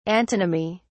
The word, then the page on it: antonymy: British English